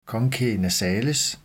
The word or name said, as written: conchae nasales